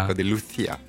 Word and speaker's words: Paco de Lucía: Paco de Lucía. – Voilà, comme ma région, Andalucía.